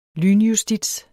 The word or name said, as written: lynjustits